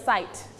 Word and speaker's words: size: คำว่าไซส์ตัวนี้ออกเสียงตัว T เพิ่มขึ้นมานิดหนึ่ง medium size แบบนี้นะคะ